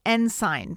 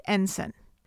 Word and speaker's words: Ensign: Now, I need to point out, when I first mentioned this title at the end of our last episode, I pronounced it the way it's spelled, as Ensign Pulver. But I'll own it, that was incorrect. It's pronounced Ensign.